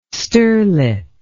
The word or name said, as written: sterlet